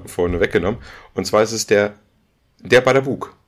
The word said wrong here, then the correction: Babadook: Babadook. Ba Baba, ach, Baba, Babadook, Babadook, genau. Da, das, da, das muss man schon richtig aussprechen, Babadook.